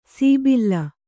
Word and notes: ಸೀಬಿಲ್ಲ: sībilla

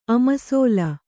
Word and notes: ಅಮಸೋಲು: amasōlu

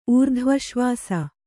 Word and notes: ಊರ್ಧ್ವಶ್ವಾಸ: Kannada

